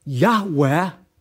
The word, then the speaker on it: Yahweh: So rather than being pronounced Yahweh, the church says Jehovah, but there is no God named Jehovah.